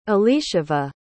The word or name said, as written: elisheva